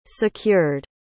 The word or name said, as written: secured